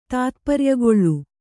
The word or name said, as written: ತಾತ್ಪರ್ಯಗೊಳ್ಳು